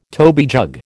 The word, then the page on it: toby jug: American English